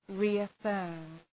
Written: reaffirm

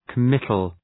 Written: committal

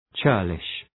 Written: churlish

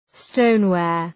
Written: stoneware

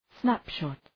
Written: snapshot

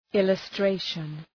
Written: illustration

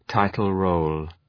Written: title-role

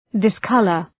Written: discolour

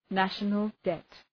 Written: national debt

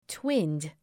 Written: twinned

